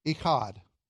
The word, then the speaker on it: Echad: This is the word, if you want to write above it in Hebrew, E-C-H-A-D, Echad. E-C-H-A-D, Echad is how you say it. And that C-H sound is what's known as a guttural in Hebrew, which gives you an opportunity to make gross sounds and sound smart.